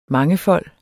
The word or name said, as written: mangefold